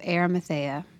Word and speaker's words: Arimathea: Joseph of Arimathea, yeah.